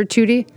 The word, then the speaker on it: tutti: Like the Facts of Life character, Tutti? – Well, T-U-T-T-I. Yeah. – Nice. I mean, just pronounce the same way.